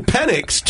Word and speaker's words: Penix: Not with the X, okay, but with the S, okay? And Michael Penix took umbrage with that.